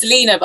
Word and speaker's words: Lina: My name's Lena, by the way. I know it's spelt Lina, but it's Lena.